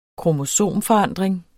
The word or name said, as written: kromosomforandring